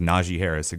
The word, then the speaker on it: Najee Harris: Uh, his name is actually pronounced Najee Harris, exactly like the Alabama running back.